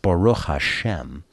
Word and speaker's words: Baruch Hashem: I mean, it's pronounced Baruch Hashem.